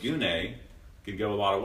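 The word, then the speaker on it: gune (γυνή): The Greek word that's used here is the word gune, G-U-N-E, pronounced gune.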